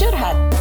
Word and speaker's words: curhat: Nope, it's pronounced curhat. It's an Indonesian term.